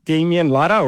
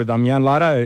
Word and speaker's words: Damian Lara: Yeah, so once again, Damian Lara, or Damian Lara, if you're angry with me, you can yell that.